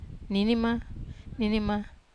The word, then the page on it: nini: wamesa